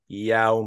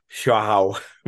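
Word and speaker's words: João: Somebody had told me to tell you that in Portuguese, which is what's spoken in Brazil, they kind of pronounce the J, so it's not Yao Morelli, it's Joe, like, or Xiao Morelli.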